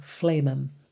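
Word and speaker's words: flehmen: Yeah, I thought it was phlegm, but it actually isn't. – Phlegm is spelled with a G. – So it's F-L-E-H-M-E-N, flamen.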